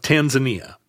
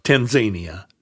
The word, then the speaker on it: Tanzania: Tanzania or Tanzania, depending on whether you are going to practice some weird pronunciation of countries.